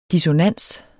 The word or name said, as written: DISSONANSERNE